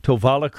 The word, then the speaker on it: Tovala: Pronounce that for me. T-O-V-A-L-A. – Yeah, Tovala.